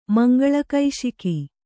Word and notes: ಮಂಗಳಕೈಶಿಕಿ: Kannada

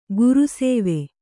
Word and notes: ಗುರುಸೇವೆ: guru sēve

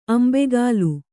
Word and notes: ಅಂಬೇಗಾಲು: kannada

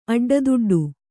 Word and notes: ಅಡ್ಡದುಡ್ಡು: aḍḍaduḍḍu

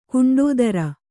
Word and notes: ಗಂಡುತೊರೆ: gaṇḍu tore

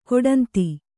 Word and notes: ಕೊಡಂದೆ: Kannada

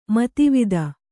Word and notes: ಮೇಧಾವಿತೆ: mēdhāvite

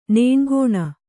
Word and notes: ನನೆಕೊನೆ: nane kone

